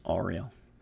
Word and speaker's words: Uriel: And then they say, Before me, Raphael, behind me, Gabriel, on my right hand, Michael, on my left hand, Uriel. Sometimes pronounced Ariel. Um, Michael, sometimes pronounced Michael or Michoel.